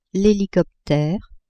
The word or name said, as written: helicoptere